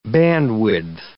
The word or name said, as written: bandwidth